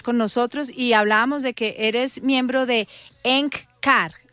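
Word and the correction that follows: Eckankar: Se pronuncia ECANCAR, S-K-A-N-K-A-R, significa ECANCAR.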